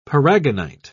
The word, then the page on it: paragonite: British English